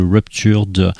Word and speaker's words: Ruptured: Ruptured, voilà comme ça se prononce, Ruptured thelabel.bandcamp.